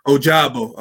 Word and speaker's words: Ojabo: How do you say his last name? Ojabayo. I'm looking forward to seeing him Ojabo. Yeah.